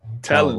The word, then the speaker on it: Telander: Telander.